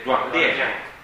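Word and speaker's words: Duan Lien (锻炼): Poi ricordiamoci, I-A-N si pronuncia I-E-N, quindi è Duan Lien.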